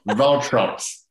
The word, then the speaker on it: Waltraut: My pronunciation there was completely wrong, but Valtrops. Helenium Valtrops.